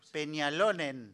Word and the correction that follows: Peñalolén: Ah, Peñalolén. Peñalolén. Peñalolén, ahí va, desde Santiago, dice que los llevamos en el corazón.